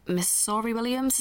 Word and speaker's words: Missouri Williams: So at the event, Lydia asked, the event was with Julia Armfield and with Miss Sori Williams, is that how you say her name? – Yeah.